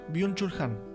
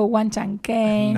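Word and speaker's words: Byung-Chul Han: Byung-Chul-Han. Byung-Chul-Han. – Yo le digo Wan Chan Ken.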